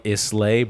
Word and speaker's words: Islay: It's spelled Islay, but it's Islay.